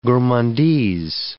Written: gourmandise

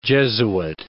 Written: Jesuit

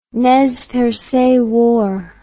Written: Nez Perce War

